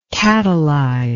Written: katalyze

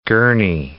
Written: Gurnee

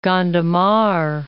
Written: Gondomar

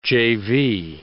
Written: jayvee

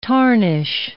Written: tarnish

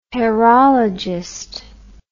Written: hairologist

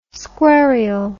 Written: squarial

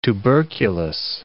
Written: tuberculous

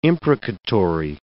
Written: imprecatory

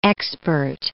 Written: expert

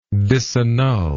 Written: disannulment

